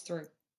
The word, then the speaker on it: Theroux: Um, am I saying it right? How do you say his, her surname? – Thoreau. Louis Theroux. – Louis Theroux. Yeah. No, it's through. Because his autobiography was called "Gotta Get Through This."